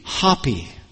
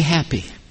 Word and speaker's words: Hapi: Well, let me say, I'll pronounce it what I think maybe it should be. Hapi. But that's H-A-P-I. And if you want to pronounce it the way it maybe sounds, you might say happy. But it's H-A-P-I, the god Happy.